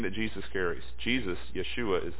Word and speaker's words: Yeshua: Yeshua, really, is the way you would pronounce it in Aramaic.